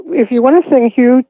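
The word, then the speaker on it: HU: One of my favorites is there's a sacred ancient love song to God called Hugh.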